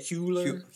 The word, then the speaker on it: Hüller: Sandra Hüller. Did I say that right? Hüller? – Yeah. – Hüller.